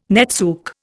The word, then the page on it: netsuke: Indian